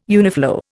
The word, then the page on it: uniflow: Indian